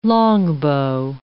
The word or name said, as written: longbow